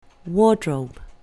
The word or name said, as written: wardrobe